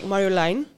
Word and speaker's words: Marjolein: Be honest, it's not even pronounced like in Dutch because it's a Dutch name. It's actually pronounced like Marjolein. But I'm like, I'm not going to. I'm not even going to say it like that.